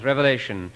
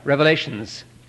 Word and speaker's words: Revelation: And maybe I should mention that it shouldn't be pronounced Revelations. They say that at Fuller Seminary, if one of the students calls it the Book of Revelations, his grade is lowered. That's a popular mispronunciation. It's singular.